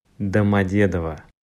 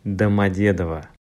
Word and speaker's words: Домодедово: Домодедово.